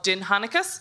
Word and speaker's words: Dindshenchas: Dindshenchas. Um, it, spelled not like it's pronounced. Uh, it's D I N D S H E N C H A S.